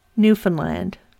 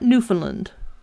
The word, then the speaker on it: Newfoundland: It's pronounced Newfoundland, not Newfoundland.